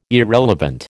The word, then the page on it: irrelevant: American English